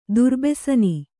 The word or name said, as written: ದುರ್ಬೆಸನಿ